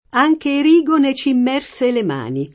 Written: Erigone

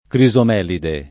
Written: crisomelide

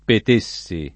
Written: Petessi

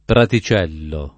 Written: praticello

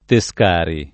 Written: Tescari

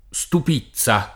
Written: Stupizza

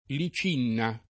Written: Licinna

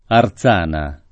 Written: Erzana

